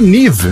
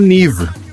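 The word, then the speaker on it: Niamh: Não, é a Nive. Eu falei, o quê? Como assim? Aí ele falou, ah. não, se escreve Niama, se fala niva Eu falei, você tá de gozação, cara.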